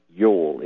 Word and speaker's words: Ewell: Now, have you ever heard of a man named Dana Yule, except it's spelled like the holiday. Like it's not. It's pronounced like the holiday, like Y U L E, but it's really spelled E W E L L.